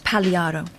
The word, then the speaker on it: Pagliaro: His name was probably pronounced Pagliaro. You don't say the G.